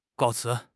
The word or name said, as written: 告辞